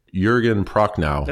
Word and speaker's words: Jürgen Prochnow: I'm going to butcher his name, even though I know it. Jürgen Prochnow. – That sounds pretty good because I was gonna okay. Pronounce the hard J, and so I would have been completely wrong.